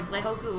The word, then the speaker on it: Leihoku: it's literally how it is spelled is how it is pronounced. so when people would say Lehuku or Lehuka or like Lehaku, it's literally L E I H O K U.